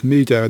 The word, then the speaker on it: Mehdoh: But a new one that caught my eye, or rather it's updated, which is why it caught my eye, is Meadow, and that's spelled M-E-H-D-O-H.